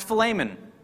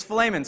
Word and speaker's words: Philemon: And I found out that it's actually pronounced Philemon according to the Greek. The E is a shorter E. It is not a long E.